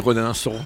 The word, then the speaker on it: Rodinson: Non, on dit Rodinson, son nom francisé,